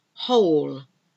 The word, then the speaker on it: whole: Whole. The whole. The whole thing is about silent letters. W-H-O-O-L-E. W-H-O-L-E. Whole. So again, you don't pronounce the W. Write. I'm going to write something down now. And this write is W-R-I-T-E.